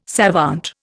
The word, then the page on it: savant: Indian